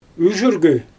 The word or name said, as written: yʒyrgy